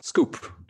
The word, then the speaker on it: SKWP: How's that pronounced then, linguistic nerd? – It's pronounced scoop. – Oh, that's pronounced scoop.